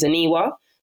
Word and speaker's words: Aniwa: But when I meet people right now in my life and I introduce my name, Aniwa Buwachi, and they say, oh, hi, Annie, and they don't even attempt to try and pronounce my name, that's when it's like, you know, like, that's when there's fighting talk.